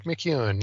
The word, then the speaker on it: McEwen: Zach McEwen? Is that how you say his name? – Zach McEwen, yep.